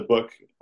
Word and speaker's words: Bök: Um, there's, there's one poet I talk about in the book. Um, his name is, is Christian Bök. His, his, last name is spelled B O K, pronounced Book.